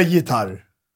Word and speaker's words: gitarr: Du uttalar det med J på svenska, gitarr. – Men du stavar det med G.